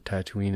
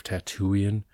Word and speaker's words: Tatooine: I think I would have to, I think there's an O short, but it's a, they at least called it Tatooine instead of Tatooian, which sounded weird.